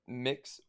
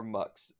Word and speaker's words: Mx: It's MX pronounced mix or mux, and that is the non-binary version of sir or ma'am.